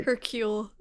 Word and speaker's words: Hercule: Hercule.